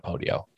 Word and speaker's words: Podio: Use Podio. And that's spelled P-O-D-I-O, Podio.